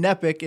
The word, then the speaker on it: Neppach: N-I-C-K. So Nick is normal, and then Nepic is N-E-P-P-A-C-H.